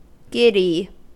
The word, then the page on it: giddy: American English